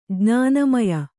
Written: ಜ್ಞಾನಮಯ

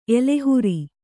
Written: ಎಲೆಹುರಿ

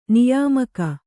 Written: ನಿಯಮಿಗ

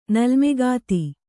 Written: ನಲ್ಮೆಗಾತಿ

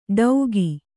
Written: ಡೌಗಿ

